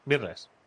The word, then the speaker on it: Myrtles: Es M-Y-R-T-L-E-S. ¿Cómo pronuncias eso? – Myrtles. – Mirles.